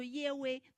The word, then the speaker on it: 耶和华: 所以他跟摩西讲，他说我是自有拥有的，自有拥有这个字发音啊，就是耶和华啊，发音就像耶和华。当然我们说，其实他的发音是叫做耶威，那我们中文翻译成耶和华。